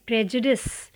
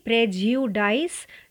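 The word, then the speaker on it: prejudice: इस वर्ड का सही प्रोनाउंसिएशन है प्रेजडिस प्रेजुडिस ना कि प्रेज्यूडाइस ना ही प्रेज्यूडिस ज्यू को पूरा नहीं कहना है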